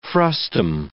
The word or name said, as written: frustum